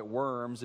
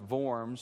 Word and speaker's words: Worms: it looks like Worms. It'd be pronounced Worms.